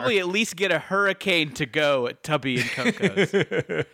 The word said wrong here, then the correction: Tubby and Coos: Oh, excuse me, Tubby and Coos. Tubby and Coos. I said that wrong.